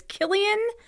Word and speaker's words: Cillian: And C-I-L-L-I-A-N. And I know for a fact that that should be a hard C. So it's Killian and then C-I-A-N, Cayenne.